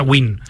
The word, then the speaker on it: Nguyen: Muchos jugadores americanos vietnamitas tienen esa, pero se pronuncia Win. Se pronuncia W-I-N, se pronuncia Win.